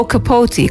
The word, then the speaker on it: Capote: Trata-se de uma novela do escritor Truman Capote, ou Capote, como se pronuncia no seu país natal, os Estados Unidos.